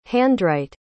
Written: handwrite

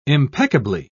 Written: impeccably

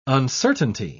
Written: uncertainty

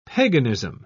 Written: paganism

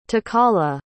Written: tokala

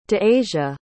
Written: de'asia

